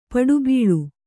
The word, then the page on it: ಪುಟಬಾಳೆ: puṭa bāḷe